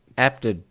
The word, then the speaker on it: Apted: Apted. – Apted.